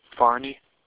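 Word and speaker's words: Fahrney: That's F-A-H-R-N-E-Y. – Steve Branny, how do you pronounce that? – Barney.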